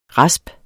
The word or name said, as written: rasp